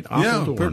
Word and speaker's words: Apeldoorn: Apeldoorn.